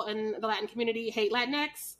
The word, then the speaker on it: Latinx: And keeping with this, you know, Latin, is that how you say it? Latin? – Latinx.